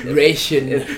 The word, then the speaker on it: Ratio: With Ratio or Ratio? – It's ration. Yes, we ration the gospel.